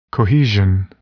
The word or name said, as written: cohesion